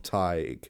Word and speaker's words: Teigue: And it is this omniscience, to use that word again, of Tig. Tig of the Lee is the original name of this story. It's spelled a very unusual way. It's spelled T-E-I-G-U-E. Now, there are many different spellings for Tig. There is the more traditional T-A-D-H-G, but it has been spelled every way from T-A-I-G to T-E-A-G-U-E, Tig.